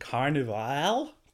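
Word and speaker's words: Carnivàle: Ja, wie man gerade gehört hat, äh, auch hier ist man sich nicht einig, wie man den Namen aussprechen soll, weil da hinten ist aber ein stummes E, deswegen heißt die Serie tatsächlich Carnival, glaube ich, mit so einer leichten Betonung auf dem A hinten.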